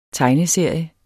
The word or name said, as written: tegneserie